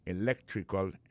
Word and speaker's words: electrical: Electrical engineering. Ça veut dire accent tombé dans electrical, là, tombé sur deuxième syllabe là. Electrical engineering. C'est sur deuxième syllabe là. Elect là, plus appuyé. Electrical engineering.